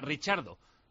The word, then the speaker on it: Ricciardo: Isa pregunta por qué pronunciamos Ricciardo como Ricciardo, que esas letras se pronuncian como CH en italiano, que la pronunciación correcta es esa, Ricciardo.